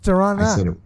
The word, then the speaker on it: Toronto: But Toronto was directly, although I do want to criticize the video ever so slightly, they pronounced Toronto wrong. – It's Toronto. It's Toronto. – Toronto. Nah. They pronounce the second T. No one here does that.